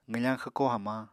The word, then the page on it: qwfañ xwko hamañ: apatani